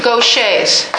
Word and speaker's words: Gaucher: So is Gaucher's, which is spelled like this. But since it's French, it's pronounced Gaucher.